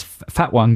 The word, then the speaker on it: Fatone: I'm not sure that's how you pronounce his name, but it's certainly spelt Fat One. And didn't he used to be in NSYNC? He's the Fat One from NSYNC. Joey Fatone.